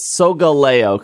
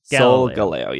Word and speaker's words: Solgaleo: And then it's Solgaleo, because it's supposed to rhyme with Galileo. Solgaleo, yeah. That one I can never remember. Okay. – Lunala. Why is it to rhyme with Galileo? Sol for sun, Leo for lion, Solgaleo.